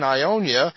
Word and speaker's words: Ionia: So, ladies and gentlemen, this is the band Ionia. And I was saying it right, so I'm very glad of that.